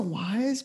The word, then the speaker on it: Weisz: How about Rachel Weisz? Am I saying that right? Is it a Weisz or a Wise? – I think it's a Wise, but I think Wise is acceptable.